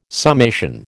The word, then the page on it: summation: American English